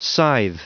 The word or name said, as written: scythe